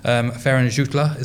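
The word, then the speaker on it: Ferran Jutglà: So Ferran Jutla, is that how you pronounce his name? – Yes, the Catalan striker.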